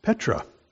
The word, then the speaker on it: Petra: And if you want to transliterate that into English, just spell it the way it sounds, P-E-T-R-O-S. You are Petros, and on this rock, different Greek word here, Petra, which if you want to transliterate that one, you would spell it P-E-T-R-A.